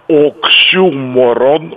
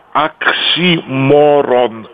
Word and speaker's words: оксюморон: Наталья Александровна Баландина, если я помню, обращала наше внимание, что есть два нормативных произнесения этого слова. Первое – оксюморон, ударение на «ю». – Да. – И второе – оксиморон. Оксиморон. Ни в коем случае никаких миронов.